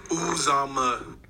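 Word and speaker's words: Uzomah: Emphasis on the first syllable. Uzama. – Uzama. And by the way, I've never heard it that way, by the way. – Just saying that to clarify the emphasis instead of seeking for you to pronounce it quite that loudly and bluntly. – Well, I just want you to know it's ooze.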